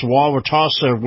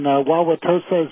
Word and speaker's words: Wauwatosa: And unfortunately, in I believe it's pronounced Wauwatosa, Wisconsin, there was a mall shooting last night where at least 8 people were wounded. The shooter is still at large. – That's exactly right, Captain. Wauwatosa is in Wisconsin.